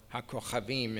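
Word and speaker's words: ha-kochavim (הכוכבים): It lists ha-kochavim, or ha-kochavim in Hebrew, the correct pronunciation.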